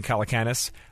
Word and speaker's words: Calacanis: There's three A's in there. Some people say Calcanis. It's technically Calacanis.